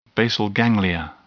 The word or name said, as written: basal ganglia